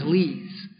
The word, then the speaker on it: Lise: my partner's name is Lise. It's a French-Canadian name. A lovely woman. It's pronounced L-E-E-Z, Z, but it's spelled L-I-S-E. Lisa with an E in the end.